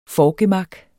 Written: forgemak

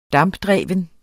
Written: dampdreven

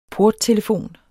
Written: porttelefon

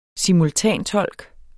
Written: simultantolk

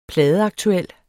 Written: pladeaktuel

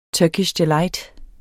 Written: turkish

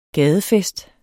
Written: gadefest

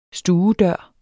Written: stuedør

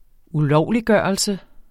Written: ulovliggørelse

